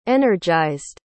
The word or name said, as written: energized